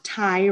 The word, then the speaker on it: Tygh: His name is Ty Runyon. Ty is spelled T-Y-G-H, which I appreciate.